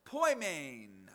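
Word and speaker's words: poimen (ποιμήν): The New Testament was written in the Greek language, and the Greek word for shepherd that we translate shepherd is pronounced poimen.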